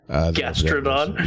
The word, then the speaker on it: Gastrodon: Gastrodon.